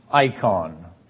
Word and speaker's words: eikon: Now, I've given in your handout the Greek word as well as its transliteration, E-I-K-O-N in the transliteration. And that would be pronounced icon in Greek.